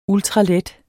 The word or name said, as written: ultralet